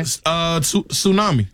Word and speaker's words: tsunami: This has a silent letter in it. – Okay. – Tsunami. – Tsunami. – Tsunami. – Tsunami. It sure does.